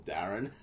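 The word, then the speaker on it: Darhún: It's spelled D-A-R-H-U-N, and there's like a little accent on the U. But when the voiceover says it, it's like, the evil force Darren will come and destroy us all.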